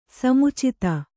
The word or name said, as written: ಸಮುಚಿತ